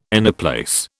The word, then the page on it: anyplace: British English